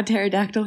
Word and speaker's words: pterodactyl: Because the P is silent. You know how pterodactyl has a P before the T?